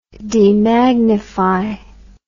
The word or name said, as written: demagnifying